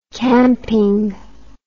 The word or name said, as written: camping